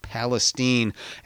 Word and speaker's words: Palestine: In Palestine, Texas, spelled like Palestine but pronounced Palestine, a small Texas town with 5 state prisons, it is by far the largest employer in that Texas county.